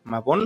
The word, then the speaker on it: Mabon: Entonces, Dianita nos va a ayudar a explicarnos esta, esta etapa que viene, que si no lo digo mal es Mabón, con B de burro, Mabón.